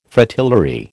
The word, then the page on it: Fritillary: American English